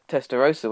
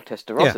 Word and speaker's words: Testarossa: I mean, it was even the case of things like the, the Testarossa, wasn't it, for a while? Or Testarossa, yeah.